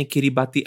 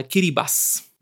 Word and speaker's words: Kiribati: Poďme na súostrovie Kiribati, mm. respektíve Eh, správne sa to vyslovuje nie Kiribati, ale Kiribas.